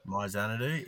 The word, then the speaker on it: My Xanadu: Is it pronounced My Xanadu? Is that how you say it? No, horse 6? – Sounds right. Yep. – Yep. – My Xanadu. Yeah, so it's got impeccable.